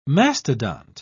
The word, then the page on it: mastodont: British English